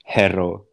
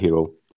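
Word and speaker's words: Herro: Mas é muito esquisito para mim dizer Tyler Harrow, ou, ou, seja lá o que for, vou manter a pronúncia que os ingleses dizem, os americanos dizem em inglês, uh, e dizer Tyler Hero.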